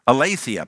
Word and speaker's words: aletheia: And the Greek word for truth there is aletheia. Aletheia probably is my better way to pronounce it.